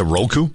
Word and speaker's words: Roku: Is it Roku? – Yeah, R-O-K-U is how it's typically spelled.